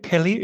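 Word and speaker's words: Peleliu: Pelio, Pelio, is that how you pronounce it? – Uh, that's a, I'll t- that's correct. I'm not believe me.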